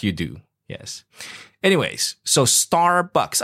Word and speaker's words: Starbucks: So a lot of people just say S-T-A, Starbucks. You know, no R. – Starbucks. – Ah, very good. There we go. So if you remember the R, Starbucks. Starbucks.